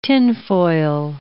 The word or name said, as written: tinfoil